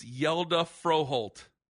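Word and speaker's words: Hjalte Froholdt: No, we've talked about this. It's Yelda Froholt. – Wait, Hilda Frau Farfisina? – It's Yelda Froholt.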